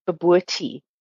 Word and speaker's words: bobotie: And then also the curry mince I make, it is actually a Cape Malay mince, and it's called, and I will spell it for you, B-O-B-O-T-I-E, babooti.